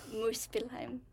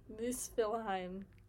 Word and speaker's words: Muspelheim: Muspelheim? – Muspelheim. – Muspelheim.